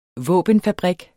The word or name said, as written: våbenfabrik